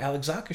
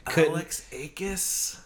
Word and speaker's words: Alexakis: And I went, oh, Alex, that's how you say it. All right. I'm trying to go through it and figure it out. – Alex Akis? – Exactly. Alex Akis.